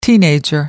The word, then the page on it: teenager: American English